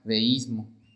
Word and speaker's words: deísmo: El peligro que ellos encontraron es el definido por la palabra deísmo. Deísmo con acento en la I.